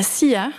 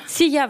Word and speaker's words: La Silla: Et vous êtes même parti en 2012 au Chili pour 19 nuits d'observation sur le télescope suisse à la SILLA. – À la SIA. – SIA, ouais, voilà.